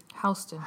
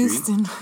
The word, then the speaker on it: Houston: how do you say it? – Houston. – Houston Street. Not Houston Street. Houston Street. Houston Street. – Houston. – Houston. East Houston Street.